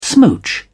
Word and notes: smooch: American English

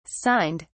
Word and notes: signed: British English

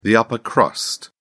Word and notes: upper crust: British English

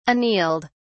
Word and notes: annealed: British English

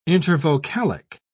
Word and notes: intervocalic: British English

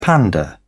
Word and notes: panda: British English

